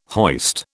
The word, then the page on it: hoist: British English